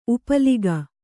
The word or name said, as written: ಉಪ್ಪಲಿಕ